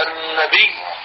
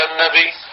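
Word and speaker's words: النبيّ: آية الكرسي، كمان اللفظ الأخير الكرسي، مثل لما نقول النبي، ما نقول النبي، لا يقال إن الله وملائكته يصلون على النبي، مش على النبي، على النبي. أكثر الخطباء في الجمعة لما يقرأوا هذه الآية ما بيقولوا اللفظ الصحيح، ما بيقولوا على النبي، بالنطق بالياء المشددة.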